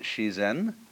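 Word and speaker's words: Shizen (自然): Dai Zinyan, or the Japanese pronounce it Shizen, is roughly when they point to all the things happening around us, that's Zinyan.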